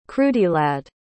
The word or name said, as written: creuddylad